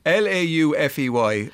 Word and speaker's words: Laufey: Laufey. – I'll just be up and down Main Street, Reykjavik. – That's a very good pronunciation of it, actually.